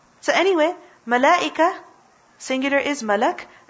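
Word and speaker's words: Malak (ملك): The word Malak is originally Mal'ak. Originally the word is what? Mal'ak. But tell me something, is it difficult to pronounce Mal'ak? It is. Why not simply say Malak? Mal'ak, Malak. What's easier? Malak.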